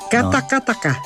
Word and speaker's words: Katakataka: Vamos talvez escutar aqui uma versão de Katakata. – Sim, Katakataka. – Katakataka. – Isso. – Falta uma sílaba.